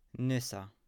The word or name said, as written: Nysa